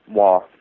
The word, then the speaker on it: War: Yes, it's spelled W-A-R, but it's pronounced Wah, like W-A-H.